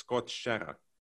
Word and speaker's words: Skottkärra: Now I know it's Skottkärra. – Yeah. – Yeah. – And that's actually, you're using my pronunciation where I live in south of Sweden, which is really nice. Because otherwise with the rolling R's, it would be like a Stockholm imitator.